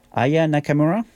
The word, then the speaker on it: Aya Nakamura: We're going to get some radio announcements, and then we're going to listen to M avec Onde Sensuelle, and then Aya Nakamura.